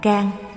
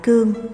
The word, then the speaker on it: Cang: Kim Cang, chữ Cang người Nam đọc là Cang, người Bắc đọc là Cương, có hai nghĩa.